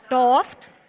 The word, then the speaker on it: daft: And telling stories and being a bit daft? Doft? How do you say it? – Doft. – We were having a debate whether it was daft or daft.